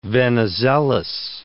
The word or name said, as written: Venizelos